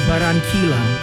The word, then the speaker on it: Barranquilla: Oh, how do you say the city in Columbia? – You say the city in Columbia. – Barranquilla? – No, Barranquilla. – Same thing.